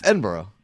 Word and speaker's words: Edinburgh: No, it's not borough. – What? Let me see. Are you talking about Scotland here? – Yeah. – Yeah, that is. That's Edinburgh. – Edinburgh. – That's how you say it. Edinburgh. – You said Edinburgh. – Well, whatever. – Like William S. Burroughs.